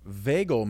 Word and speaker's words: vagal: Like V-A-G-A-L and then maneuver. – Vagal. – Vagal. – It's like bagel with a V. – Oh, vagal maneuver. Okay, now I'm thinking of a different part. – Vagal? – Or no.